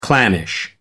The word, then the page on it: clannish: American English